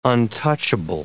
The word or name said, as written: untouchable